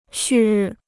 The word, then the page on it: 旭日: xù rì